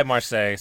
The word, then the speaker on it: Marseille: Can I dirty tackle you for your pronunciation of Marseille? – I'm pretty sure I said it just how you just said it. – You said it like Palace of Versailles. – I didn't mean to. – Marseille, Versailles, Marseille. Same difference.